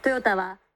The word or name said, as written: toyata